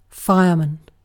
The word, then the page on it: fireman: British English